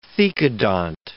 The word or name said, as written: thecodont